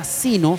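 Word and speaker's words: Zeno: También usted nos puede escuchar en la aplicación, descarga la aplicación que se llama Sino, se escribe Z-E-N-O, y tú buscas en la radio Gracias Sublime Chillán y apareceremos también para que nos puedas escuchar.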